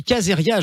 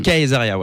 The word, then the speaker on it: Caesaria: On dit Caesaria, ouais. – Caesaria. Ouais, c'est ça. Ça s'écrit comment pour savoir, pour vous aller vous retrouver sur les réseaux C'est C-A-E-S-A-R-I-A, e comme César, Caesar, IA à la fin.